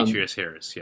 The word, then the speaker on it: Demetrius Harris: Yeah, Demetrius Harris.